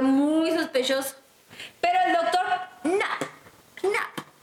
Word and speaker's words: Knapp: Knapp, porque es K-N, como cuando pronuncias no, sí, no, Knapp.